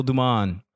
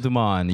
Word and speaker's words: Dumon: It's The Real, last name D-U-M-O-N. The Real Dumon. I kept it simple. – Yep. Well, that's at The Real Dumon, you can find me.